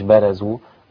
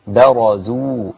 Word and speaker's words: برزوا: ولما برزوا، مش برزوا، برزوا، الراء هنا مفخمة قولا واحدا عند كل القراء.